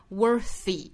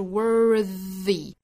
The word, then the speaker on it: worthy: 我们注意 worthy 这个词哈，它 th 发的是浊辅音，不是发 worthy，应该是 worthy。一定要注意区分那个 worth 那个单词，那个是读成 worth 那个单词的尾部是清辅音，但是这里面它是浊音 worthy。